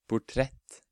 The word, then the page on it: portrett: Norwegian